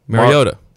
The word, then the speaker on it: Mariota: Pronounce his name for me, please. – Mariota. – Thank you.